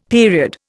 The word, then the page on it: period: Indian